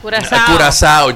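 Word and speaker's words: Curaçao: Óyeme, se escribe Curacao, pero se lee Curazao.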